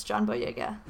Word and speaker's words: John Boyega: But apparently there's one slow Loris out there in the world, and it's John Boyega. I don't know if that's how you say his name. I don't know. That is how you say it.